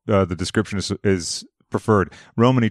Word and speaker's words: Romani: Because I know that you, is it Romani? Is that how you pronounce it? – Yeah, Romani, yeah.